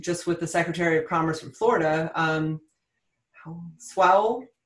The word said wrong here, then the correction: Sowell: did I say it right there, Kim? I was practicing. – Sowell, like how? – Sowell, yes. Secretary Sowell and others,